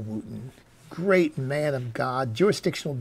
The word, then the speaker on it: Wooden: His last name is spelled, by the way, W-O-O-D-E-N, but it's pronounced Wooten.